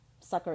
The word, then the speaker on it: succor: Succor is how you pronounce that. It's S-U-C-C-O-R.